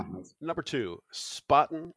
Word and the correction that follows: Spaten: Spotten, right? My pronunciation. So these are both Munich beers. – Spaten or Hackerbräu? – Yes. – Oh my God. – Sorry. – Really? – Sorry for my American pronunciation.